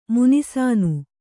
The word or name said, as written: ಮುನಿಸಾನು